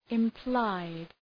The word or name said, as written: implied